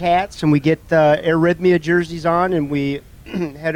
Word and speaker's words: Arrhythmia: Yeah, and part of that will be the Arrhythmia, right? Did I pronounce that right? – You did, yes.